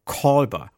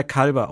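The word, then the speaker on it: Culber: Und auch ich muss das irgendwann mal lernen, dass der Kolber ausgesprochen wird. Ich glaube, der wurde am Anfang von Discovery tatsächlich im Englischen mal Kalber ausgesprochen. Und das hat sich bei mir so eingeprägt, aber heutzutage sagen alle Kolber.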